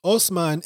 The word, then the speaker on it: Osman: Osman is the Turkish pronunciation of the Arabic word Othman.